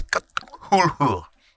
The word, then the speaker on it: Cthulhu: The proper pronunciation of that word is Cthulhu.